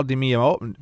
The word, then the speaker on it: DiMeola: And Al DiMeola, how you say his name? – DiMeola.